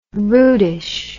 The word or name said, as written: rudish